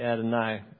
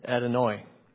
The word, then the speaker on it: Adonai: Some might say Adonai, but phonetically, you would say Adonai, which comes from the singular Adon.